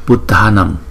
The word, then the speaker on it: Buddhanam: Buddhanam Buddhanam Buddhanam Buddhanam Buddhanam. Thấy không? Vì chữ D và chữ H đứng chung chúng ta đọc là Da Da.